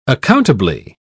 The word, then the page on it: accountably: British English